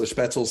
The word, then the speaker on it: Spoetzl: And so Spetzel sounds German, in case you don't know German, Spoetzl is how it's spelled.